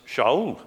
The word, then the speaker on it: Shaul: They call him Saul, but they don't pronounce it that way. They pronounce him as Shaul again.